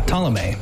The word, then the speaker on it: Ptolemy: I'm going to butcher this, but Ptolemy? – Oh, yeah, Ptolemy.